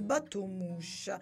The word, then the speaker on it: Bateau Mouche: È in francese, sì sì, beh, è scritto così come in francese, le Bateau Mouque CHE che si pronuncia le battumush, così bello, così dolce.